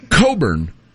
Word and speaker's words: Cockburn: Well, her real last name is Coburn, but it's spelled Cockburn. – Right. – Like the guy, remember the guy that played at Illinois, Kofi Coburn? – Yeah. – But it was spelled Kofi Cockburn.